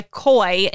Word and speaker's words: Quoi: who is now going by Koi instead of Sequoia. It's spelled Q-U-O-I, which is also the French word quoi, which means what.